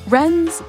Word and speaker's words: Rens: Wrens, by the way, it's not the bird. It's spelled R-E-N.